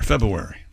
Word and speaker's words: February: You drop the R. February. – Aren't you supposed to? February. – No, you're supposed to say February, I believe. All of the letters are supposed to be pronounced.